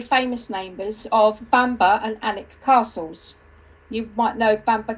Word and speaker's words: Alnwick: But if you're not local, you'll think it's Alnwick. It's spelt A-L-N-W-I-C-K, but okay, you call it, you say Alnwick.